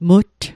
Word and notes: mutt: Mi'gmaq

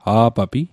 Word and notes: a'papi: Mi'gmaq

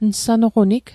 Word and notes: nsanoqonig: Mi'gmaq

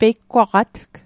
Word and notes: peigwaqatg: Mi'gmaq

